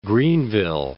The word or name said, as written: Greenville